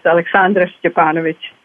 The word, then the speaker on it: Aleksandra Scepanovic: It's Aleksandra Scepanovic.